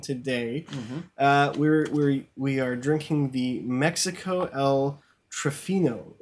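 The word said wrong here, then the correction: Triunfo: How do you say that? – Oh, let's see. I think it's a Trunfo. Trunfo. Yeah, that's a tricky one.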